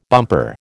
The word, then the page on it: bumper: American English